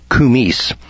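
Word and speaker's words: koumiss: Kumis, I guess it is, K-O-U-M-I-S-S-S. It's pronounced kumis. K-U-M-E-E-C-E.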